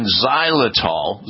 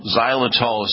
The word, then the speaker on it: xylitol: if they are not rich in xylitol, xylitol is spelled with an X, X-Y-L-I-T-O-L, xylitol,